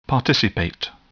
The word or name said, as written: participate